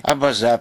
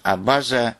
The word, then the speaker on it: абаза: Я ответил на это, что слово «абаза» с ударением на последнем слоге действительно распространилось в Абхазии лет 25 назад.